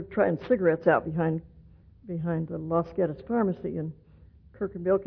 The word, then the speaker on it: Los Gatos: Well, I was born and raised in a small town in California called Los Gatos, which if you speak Spanish, you know I didn't say it correctly because it was settled by Midwesterners, and it should be pronounced Los Gatos, but Midwesterners decided to call it Los Gatos, so that's what it's called.